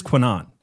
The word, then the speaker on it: QAnon: So the first thing I have to say about QAnon is that you are pronouncing it wrong. – I know. Spencer told me. – Okay. Our friend Spencer Clavin, who's done a show with us, informed us that it is pronounced QAnon. And that is how we are going to pronounce it, if I can remember.